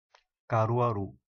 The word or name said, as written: Caruaru